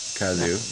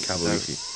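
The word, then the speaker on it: Kazu Kibuishi: What's his name? How do you say it? What do you got? – Kazoo? – Yeah. Kazu. – Kazu? Kabuishi? Is that how you... – Kabuishi, yeah.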